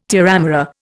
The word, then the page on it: terramara: Indian